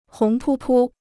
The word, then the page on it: 红扑扑: hóng pū pū